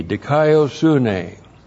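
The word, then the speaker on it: dikaiosunē: Looks like that in the Greek, D-I-K-A-I-O-S-U-N, long E, dikaiosune.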